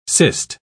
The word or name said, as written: cist